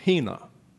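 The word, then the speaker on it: hina (ἵνα): There is an important word in John 3:16, a conjunction that is pronounced hina.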